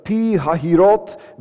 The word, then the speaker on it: Pi Hahiroth: Then the Lord said to Moses, "Tell the people of Israel to turn back and encamp in front of Pi Hahiroth, between Migdol and the sea, in front of Baal Sephon."